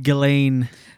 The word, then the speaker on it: Ghislaine: We always say Ghislaine in I know. – French, ugh. But she pronounces it Ghislaine Maxwell. – Maybe because she's from the UK.